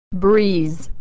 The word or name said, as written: breeze